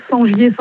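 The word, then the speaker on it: Sengier: Sur mon nom, Sangier Sandrine.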